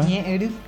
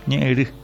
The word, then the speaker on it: Ñeeri: programa Ñeeri. ¿Así se pronuncia? – Ñeeri. – Ñeeri. Sí. A las 10, entrega de nuevos equipos médicos de alta complejidad en el Instituto Nacional de Cardiología Juan Catoni.